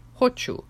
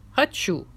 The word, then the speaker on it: хочу: One politician found it hilarious that in Ukrainian, the stress in the first person singular word for want is on the first syllable, хочу, while in Russian, it's on the second syllable, хочу.